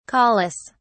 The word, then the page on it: caulis: British English